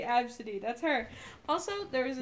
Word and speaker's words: Abcde: Her name is Absidy. Guess how it's spelled. – Absidy. – A B C D E. She's named after her father's cat. Her father's cat was named Absidy. A B C D E. That's her name.